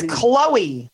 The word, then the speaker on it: Khloe: It's Khloe. – Yeah, but what's the H then? Is it silent? – Yes.